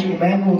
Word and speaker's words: Emanuel: Jones, Walter Emmanuel Jones on Facebook. Emmanuel is spelled E-M-A-N-U-E-L, one L, one L, not a little cool black his name.